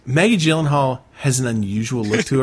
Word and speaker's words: Gyllenhaal: Love and Other Drugs featuring Anne Hathaway and Jake Gyllenhaal. God, I hope I said that right. – Gyllenhaal.